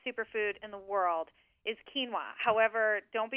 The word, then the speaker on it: quinoa: And it's spelled quinoa, quinoa, pronounced quinoa.